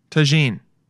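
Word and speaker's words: Tajín: How to pronounce Taine? – Tajin. – Okay. Tajin. Yeah, I was close. Anyone who has, because Tagine would be, anyone who has 9 miniature Tajines, like that's how you say it, right?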